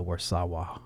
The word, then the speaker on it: Warszawa: It's like Warsaw, but uh, Warszawa.